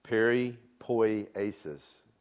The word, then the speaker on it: peripoiesis: It's actually pronounced peripoiesis. Can you say that with me? Peripoiesis. Peripoiesis. Peripoiesis.